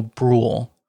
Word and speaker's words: Brühl: One, Wyatt Russell, who played John Walker, and Daniel Brühl. I think I'm pronouncing that correctly. It's B-R-U with the two dots over it as a German name, H-L. So I think that makes the U a long U.